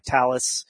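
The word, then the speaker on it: Thales: They're companies like Talus, which is how you pronounce this, that sell hardware into data centers to do cryptography.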